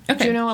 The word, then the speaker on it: Juneau: She moved up to, I'm not even sure if I'm saying it right, Juneau? – Yeah. Juneau, Alaska.